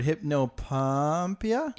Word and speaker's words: hypnopompia: Hypnopompia. Yeah, hypnopompia. – If okay. I'm saying it right. – Sure. Uh, uh, Yep, that's it.